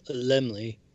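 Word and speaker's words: Laemmle: Carl Zemele presents Frankenstein, produced by Carl Zemele Jr., copyright MCXMXXXXI, Universal Pictures Corp., Carl Zemele presents, Incorporated. – It's Carl Zemele. – Yes. – And You put two letters like that and expect me to know how to say it.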